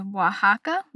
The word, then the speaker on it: Oaxaca: According to Google, it's pronounced Oaxaca, Mexico. I feel like I'm pronouncing that wrong. It's O-A-X-A-C-A.